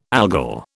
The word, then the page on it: Algol: British English